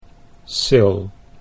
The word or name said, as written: Syl